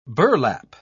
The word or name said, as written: burlap